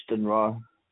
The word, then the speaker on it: Stranraer: Yeah, and what Stranraer. – area? – That's Stranraer.